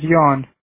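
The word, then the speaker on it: Yan: My name is Jan. – Can you spell that for me, please, Jan? – Yeah, that's Y-A-N.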